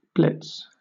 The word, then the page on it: blitz: British English